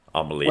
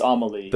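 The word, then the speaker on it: Amélie: Amelie. Well, I don't know if I'm saying it right. – I think it's Amelie.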